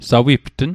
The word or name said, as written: sawiptn